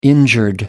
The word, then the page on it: injured: American English